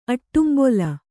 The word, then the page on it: ಆಡುಂಬೊಲ: āḍumbola